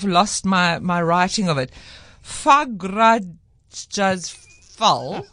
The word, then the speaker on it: Fagradalsfjall: So Fagradalsfjall is actually apparently how you pronounce it. It's a mountain that is erupting. And there's no C or K in this word in front of me. – But that's how you pronounce. But that's why I thought it was so interesting. So it's spelled Fagrad Alfjall. But when I Googled it, the man on the YouTube video said it's pronounced Fagradalsfjall.